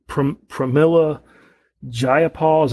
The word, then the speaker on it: Pramila Jayapal: And then, you know, the Pramila Jayapal, is that how you say her name? – That's close enough.